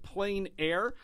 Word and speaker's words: Plein Air: And for those of you who have been listening to this show, you might have heard me pronounce it Plein Air, but it is Plein Air Washington Artists, P-L-E-I-N.